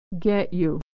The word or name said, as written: get you